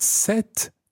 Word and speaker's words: cet: But set is spelled C-E-T. Cet après-midi.